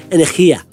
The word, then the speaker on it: Energia: I mean, Zach was on the podcast a while back and he knows a bit of Spanish and he said that's how he's pronounced it. Energia.